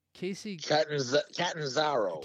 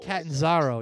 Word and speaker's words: Catanzaro: And then I'm gonna pronounce this wrong, but Casey Catanzaro. – Catanzaro, yeah.